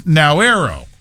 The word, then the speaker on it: Nauru: Its people will be known as De Noreo instead of Narian, commonly known abroad by the pronunciation of Nauru. The name is spoken as Nauero.